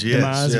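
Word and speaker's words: DeYmaz: And you had referenced one book in your article by Mark, I don't actually know how to say his name, Dimas? – Dimas, yes.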